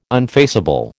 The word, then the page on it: unfaceable: American English